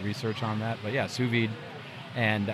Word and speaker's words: sous vide: Sous vide. – Sous vide. Spell that. – Do you know? It's French. S-O-U-S and vide. V-I-D-E. Sous vide.